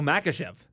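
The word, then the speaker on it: Makhachev: Menchikov? – Yeah, the co-main event. – Oh, Makachev.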